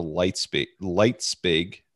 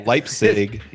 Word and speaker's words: Leipzig: It was in his debate with Philip Eck, I believe at the Leipzig. I'm saying that wrong. – Leipzig.